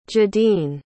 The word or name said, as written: jadine